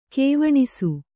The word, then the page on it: ಕವನಿಸು: kavanisu; Kannada